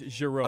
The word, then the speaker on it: Jarreau: I mean, it's that's not Jarreau. You're doing just, it with a hard J. It's Jarreau.